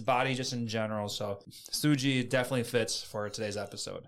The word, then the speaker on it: suji: Today's Lessons is going to be the Japanese word for muscle, and that is suji. Suji is spelled S-U-J-I